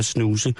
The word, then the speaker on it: snooze: Snus. Snus. – Jeg vidste heller ikke, det var sådan, man skulle udtale det. Snus. – Det er det.